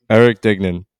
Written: Erick Duignan

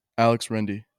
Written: Alex Rende

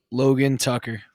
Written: Tucker

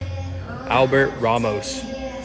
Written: Albert Ramos

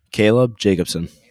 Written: Jacobson

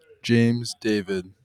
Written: David

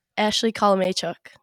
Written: Kolomechuk

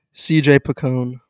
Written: C.J. Picone

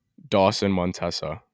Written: Dawson Montesa

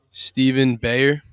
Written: Stephen Bayer

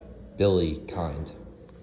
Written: Billy Kind

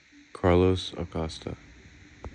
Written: Carlos Acosta

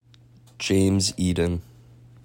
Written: James Eden

